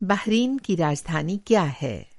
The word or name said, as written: बहरीन